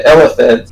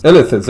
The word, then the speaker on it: Illithids: The Illithids. – Illithids, there you go. I never knew how to pronounce that.